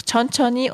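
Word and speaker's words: chonchoni (천천히): Und chonchoni ist so, man schreibt chonchonhi, das H in der dritten Silbe, finde ich, hört man gar nicht. Man sagt einfach chonchoni und dann marejuseyo.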